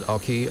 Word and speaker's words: OCCI: I played at, pretty sure you pronounce it Occi, O-C-C-I, which is probably one of the main, I'd say probably the main noise venue to play in Amsterdam.